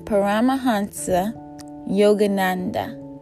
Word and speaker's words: Paramahansa Yogananda: It's by Paramahansa Yogananda.